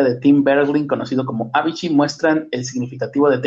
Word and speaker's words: Avicii: Mm, un inquietante documental muestra a Avicii, quedamos que se llama, que se pronunciaba Avicii, en lucha por mantener sus ojos abiertos.